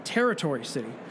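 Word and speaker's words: Tarotory City: The city is pronounced Territory City, as in tarot, like the card. – Right. – But it's written as tarot, like the card. And Tory, as in architectural and infrastructure terms.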